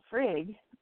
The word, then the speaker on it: Frigg: The name Frigg, pronounced with the Dutch usage of the double G, which is more of like a hard Y, did come up in the same context, but other names were more common.